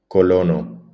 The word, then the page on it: colono: Spanish